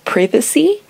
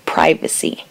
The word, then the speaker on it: privacy: Un ejemplo sería la palabra privacy, que significa privacidad, y en Inglaterra se pronunciaría privacy y en los Estados Unidos se pronunciaría privacy.